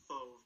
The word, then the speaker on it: Fauve: I assume it's pronounced Fauve, because anytime I see an A-U, it always has like an O sound.